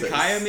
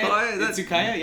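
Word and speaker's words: Izakaya: Itsukaya, maybe, I think, is that how you say it? Itsukaya? Yeah, that sounds about right.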